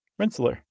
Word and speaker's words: Rensselaer: Yeah. Rensselaer, right? – I have no idea how to pronounce that. – R-E-N-S-S-E-L-A-E-R. Rensselaer. Yeah, that's what I'm going with.